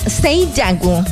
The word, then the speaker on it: Seijaku: Es que mira, se escribe Seijaku, pero se pronuncia Seiyaku. Entonces, para que a veces la gente se confunde, dice, ¿cómo se llama el local? Seijaku Sushi. Se escribe Seijaku Sushi, pero se pronuncia Seiyaku Sushi.